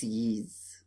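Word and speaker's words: Ys: Yeet. – Yeez. – Yeez, it's Yeez. It's the one that's spelled Y-S.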